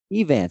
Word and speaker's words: Evant: Division 2, the Blanket Tigers take out the Elks of, is it Avant? – Evant. – See, I never say it right.